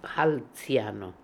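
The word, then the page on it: Hanziano: Russian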